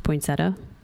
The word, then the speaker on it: poinsettia: I thought it was poinsettia. – That's how it's spelt, but mm-hmm. It's usually pronounced poinsettia.